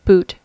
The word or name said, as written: boot